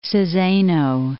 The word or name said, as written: Saseno